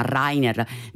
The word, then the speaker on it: Rainer: Nel frattempo continuano ad arrivare i vostri messaggi, qualcuno di voi mi corregge per la pronuncia di Rilke, che non è Rainer come ho detto io, ma Rainer.